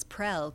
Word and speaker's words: Prell: Yes, my name is Letty Prell. I'm the Director of Research with the Iowa Department of Corrections. Letty is spelled L-E-T-T-I-E. My last name is Prell, P-R-E-L-L.